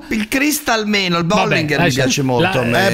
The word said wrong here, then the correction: Bollinger: Bollinger si pronuncia, Bollinger non sei neanche capace di parlare in francese, fenomeno.